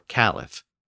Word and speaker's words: caliph: known as a caliph or caliph.